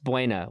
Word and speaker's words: Buena: It's pronounced Buena.